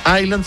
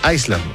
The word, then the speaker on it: Island: Claro, es como Island, que en inglés se pronuncia Island sin la S, se queda mundo. Hay gente que dice Iceland, no, es Island, como Coney Island de Nueva York, o digamos, claro, lo mismo que design, no es design, así que Foreigner.